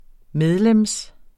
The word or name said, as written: medlem